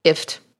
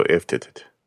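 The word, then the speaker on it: IFTTT: The main one that I use is If This Then That, which according to their website is pronounced IFT, like lift without the L. – I just go IFT.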